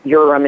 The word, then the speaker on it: Uram: It's pretty much any way you say it's okay, because half the family says Uram and half the family says Uram.